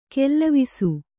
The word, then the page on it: ಕಾಲವಶ: kālavaśa